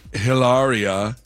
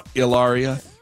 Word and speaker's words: Hilaria: How many kids do you believe that Alec and Hilaria, is that how you say it? Ilaria? – I think it's Ilaria. I think it's the H is silent.